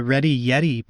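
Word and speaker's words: Reddy Yeti: you could check out the Ready Yeti podcast. It's not spelled quite how you might think it is spelled. It's R-E-D-D-Y, and then Yeti is Y-E-T-I.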